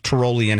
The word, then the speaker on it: Tyrolean: And according to Google is pronounced Tyrolean hat.